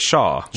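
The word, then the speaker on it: Chaough: They pronounce it Shaw. When they say the full name of the company, yeah. – The company, Cutler, Gleason, and Shaw, that's what they pronounced it.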